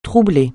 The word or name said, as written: troubler